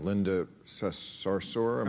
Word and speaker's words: Sarsour: I know you were having a bit of a Twitter spat sometimes with this woman, Linda Sarsour. Am uh-huh. – I saying that right? – Yeah, yeah.